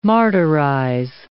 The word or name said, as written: martyrize